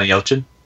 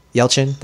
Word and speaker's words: Yelchin: How do you say his name? Last name correctly? – Anton Yelchin. – Yelchin. That's right.